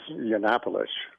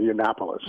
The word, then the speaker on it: Yiannopoulos: They have brought in people like Milos Yiannopoulos. I always get his name wrong, Yiannopoulos.